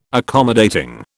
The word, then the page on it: accommodating: British English